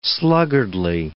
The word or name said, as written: sluggardly